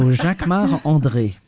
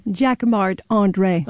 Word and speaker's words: Jacquemart-André: Jacquemart-André. You say it like André. – A Frenchman. Jacquemart-André. – Of course, because I'm French. So Jacquemart-André.